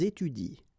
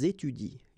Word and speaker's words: étudient: So you will get ils étudient. All right? Ils étudient. You don't pronounce that.